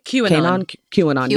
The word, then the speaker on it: QAnon: And them talking about asking him about, was it, how you pronounce it? QAnon, right? – QAnon.